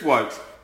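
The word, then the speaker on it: Woakes: Chris Wokes, rhymes with joke for a good fucking reason.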